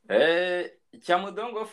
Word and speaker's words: Cyamudongo: Cyamudongo forest. I see it's only 19 square kilometers, which is quite small for a forest. – Cyamudongo forest is a small fragment forest with the 19 kilometer square of Nyungwe National Park.